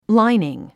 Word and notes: lining: lǽiniŋ; ラァイニン

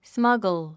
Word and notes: smuggle: smʌ́ɡl; スマゴォ

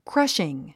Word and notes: crushing: krʌ́ʃiŋ; クラッシン

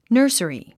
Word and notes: nursery: nə́ː r s ə ri; ナーサリィ

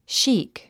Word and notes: chic: ʃíːk; シーク

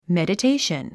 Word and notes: meditation: mèdətéiʃən; メデテェイション

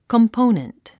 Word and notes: component: kəmpóunənt; コンポゥネント

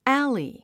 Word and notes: alley: ǽli; アァリィ

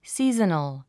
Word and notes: seasonal: síːz ə nl; スィーザナル

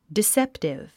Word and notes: deceptive: diséptiv; ディセプティブ

